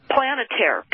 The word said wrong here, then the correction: Palantir: Palantir, has secretly been using New Orleans to test its predictive policing technology on, and it began in 2012.